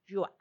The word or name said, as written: yua